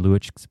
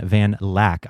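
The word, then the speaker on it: van Luijk: There is a quote from in Ruben van Luijck's, actually it's pronounced Ruben van Lack. I just found that out, but it looks like Luijck.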